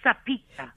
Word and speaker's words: Sapieka: I get the feeling that I just completely butchered your surname. – It's Sapieka.